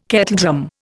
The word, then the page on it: kettledrum: Indian